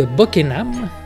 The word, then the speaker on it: Buckingham: Donc, de retour à la cour du duc de Buckingham.